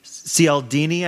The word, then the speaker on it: Cialdini: It's the book on influence and persuasion by Robert B. Cialdini, I believe it's pronounced.